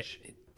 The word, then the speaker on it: Leitch: I believe it's pronounced, but I'm not sure. – Lech. – It's definitely Lech.